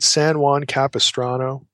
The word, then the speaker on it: San Juan Capistrano: San Juan Capistrano.